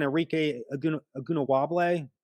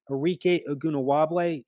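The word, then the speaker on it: Arike Ogunbowale: remember when Arike Ogunawable, finally getting that name pronounced right, Arike Ogunawable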